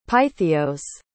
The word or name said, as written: pythios